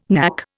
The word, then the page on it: knack: Indian